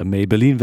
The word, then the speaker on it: Maybelline: Pete Doherty und seine Baby Shambles mit dem Track Maybelline, nicht Maybelline, wie ich dachte, Maybelline wird's ausgesprochen.